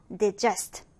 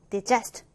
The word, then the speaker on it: digest: 자, 이 단어는 발음이 두 가지가 가능하네요. digest, digest 또는 digest인데요. 자, 일단 기본적으로 음식을 소화하다 라는 의미를 갖고 있습니다.